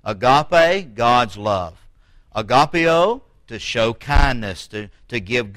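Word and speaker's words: agapaō: The second type of love found in the New Testament is agapeo, or agapeo. It's A-G-A-P-A, long O, agapeo.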